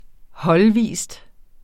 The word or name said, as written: holdvis